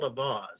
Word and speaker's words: Auxvasse: Yeah, I grew up in Callaway County, Missouri, in a little town called Avaus, and that's spelled A-U-X-V-A-S-S-E.